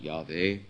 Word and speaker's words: Yahweh: First of all, the title Lord, used by the Jews as a substitute for the name of God, Jehovah, or as scholars say, it should be probably pronounced Yahweh.